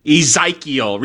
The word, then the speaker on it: Ezekiel: Ezekiel. – There's an I, it's just not pronounced, it's not a hard I. – Yeah, not a hard I sound. It's an E sound.